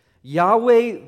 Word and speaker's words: Yahweh: Scholars today believe it's pronounced. And Yahweh is the more universally accepted by most scholars.